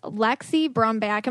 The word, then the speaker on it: Lexi Brumback: I am here with Lexi Brumback.